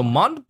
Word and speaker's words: Mont: M-O-N-T, Mont. Okay. I'm pronouncing it Mont. Technically in French, there's no T. So it's Mon. – Mon. Oh. – Yeah. So M-O-N-T means mountain.